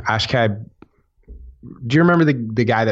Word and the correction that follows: Akshay Bhatia: Yeah, the left-hander, Akshay Bhatia.